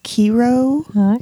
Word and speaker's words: Cairo: So, like, Cairo is Kiro.